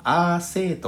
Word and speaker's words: AC: AC インドネシア語ではアーセイ」と発音します